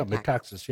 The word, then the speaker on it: Metaxas: Am I saying that right? – Yeah, Metaxas, yes.